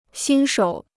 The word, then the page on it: 新手: xīn shǒu